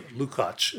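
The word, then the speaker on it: Lukács: And also, Gheorghe Lukacs, Lucacs. And I think David Gordon told me it's called Lukacs, as I pronounced it.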